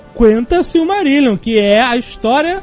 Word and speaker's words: Quenta: É, é Quenta ou Quenta? – Quenta. Se pronuncia é, gente... todas as letras. Fala, fala-se todas as letras, é isso mesmo.